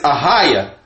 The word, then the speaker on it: Ahayah: God's name is Ahayah. – Spell that, brother. – A-H-A-Y-A-H.